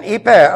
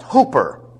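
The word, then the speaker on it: hyper (ὑπέρ): And hyper, unfortunately, it's pronounced hooper in Bible schools.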